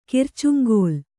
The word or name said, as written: ಕರ್ಚುಂಗಲ್